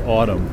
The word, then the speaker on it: ATUM: But they have a new album out called Autumn, A-T-U-M. I would think to pronounce it Atum, but they say it's pronounced Autumn.